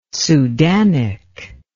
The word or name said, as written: sudanic